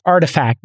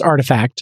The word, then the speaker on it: RTFKT: In a totally non-related story, Nike just acquired an NFT studio pronounced Artifact. It's spelt R-T-F-K-T, but it's pronounced Artifact.